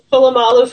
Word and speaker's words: Polamalu: So Troy Polamalu, is that how you say it? – Polamalu from the Pittsburgh Steelers.